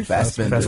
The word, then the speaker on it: Fassbender: Fassbender? – Fassbender.